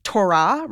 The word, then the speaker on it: Torah: people say Torah, right? That's like Hebrew with the emphasis on the second syllable.